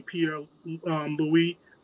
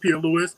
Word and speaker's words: Pierre-Louis: You can find me at Gary, Gary, last name Pierre Louis. Most people pronounce it Pierre Louis. PIERRE-LOUIS.